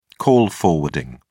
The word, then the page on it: call forwarding: British English